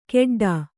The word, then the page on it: ಕೆಡ್ಡಾ: Kannada